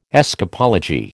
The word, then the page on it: escapology: American English